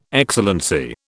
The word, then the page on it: Excellency: British English